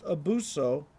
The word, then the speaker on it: abyssos: The term bottomless is translated from the Greek word abyssos, mostly pronounced abuso, and literally means without depth.